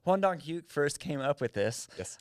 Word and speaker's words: Hwang Dong-hyuk: It's Hwang Dong-hyuk. – I think you're pretty close, yeah.